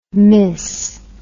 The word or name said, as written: mis